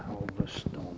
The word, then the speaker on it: Alvaston: A-L-V-A-S-T-O-N, Alverstone.